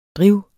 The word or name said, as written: driv